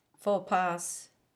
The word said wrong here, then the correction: faux pas: It's pronounced faux pas.